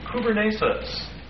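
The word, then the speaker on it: Kubernesis (κυβέρνησις): Actually, the word governments there is the Greek word kubernesis. Kubernesis, that's spelled K-U-B-E-R and long E-S-I-S. Kubernesis.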